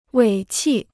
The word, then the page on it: 尾气: wěi qì